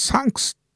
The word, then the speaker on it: THX: これ THX ってのはサンクスっていう風に発音するんですよね。